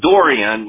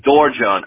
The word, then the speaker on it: Dorjan: It's Dorian. It's Dorian, but over here they call me Dorjan.